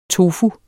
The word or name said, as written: tofuen